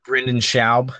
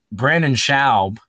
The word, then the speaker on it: Brendan Schaub: I like on the on the so in the YouTube comments, uh, he's always like he, the fans always mispronounce his name because Theo Von, another comedian for the listeners, uh, Theo Von always says like, "What's his name? Brandon, Brandon Schaub."